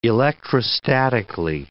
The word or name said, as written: electrostatically